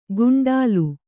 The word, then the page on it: ಗುಂಡಾಲು: guṇḍālu